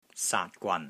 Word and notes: SAAT3 GWAN3: Cantonese

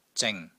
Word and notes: ZING3: Cantonese

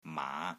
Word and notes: MAA5: Cantonese

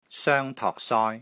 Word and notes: SOENG1 TOK3 SOI1: Cantonese

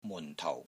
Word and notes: MUN4 TOU4: Cantonese

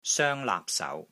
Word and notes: SOENG1 LAAP3 SAU2: Cantonese

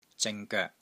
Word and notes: ZING3 GOEK3: Cantonese

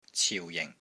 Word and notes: CIU4 JING4: Cantonese

